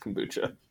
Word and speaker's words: kombucha: It's important to me that you know that it's pronounced kombucha. – I was about to say kombucha, but I was like, that can't be right.